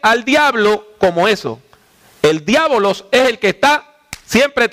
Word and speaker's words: diabolos: Lo pronuncié en griego, diabolos.